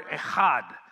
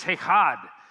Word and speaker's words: echad: It is the Hebrew word echad. So if you want to pronounce that word, just clear your throat. Go, and you'll pronounce the Hebrew word. It is echad.